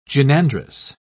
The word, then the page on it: gynandrous: British English